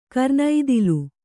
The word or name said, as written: ಕರ್ಣತಾಳ